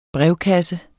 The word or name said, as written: brevkasse